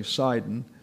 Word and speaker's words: Sidon: Sidon is the correct pronunciation. We have a tendency to say Sidon, but it's Sidon.